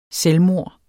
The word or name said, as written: selvmord